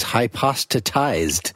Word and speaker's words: hypostatized: And he says, and one can have a Kantian or Bergsonian experience of this hypostasized Now, is that hypostasized? How do I say that? – Well, see, this is actually spelled with two T's, hypostatized.